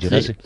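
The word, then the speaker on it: Djerassi: Gerasi.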